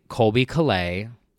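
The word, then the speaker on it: Colbie Caillat: So one of my favorite Christmas songs is an underrated gem by Colby Calais.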